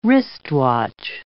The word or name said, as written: wristwatch